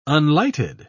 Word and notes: unlighted: British English

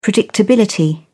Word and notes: predictability: British English

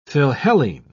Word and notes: philhellene: British English

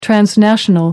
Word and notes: transnational: American English

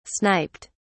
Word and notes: sniped: British English

